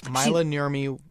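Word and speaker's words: Maila Nurmi: Myla Nurmi was in the film Plan 9 from Outer Space.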